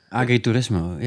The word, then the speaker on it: Agriturismo: Agriturismo, was it? Am I pronouncing it correct? Is that what it's called, guys? You both will know about it more than me. – Agriturismo, yeah.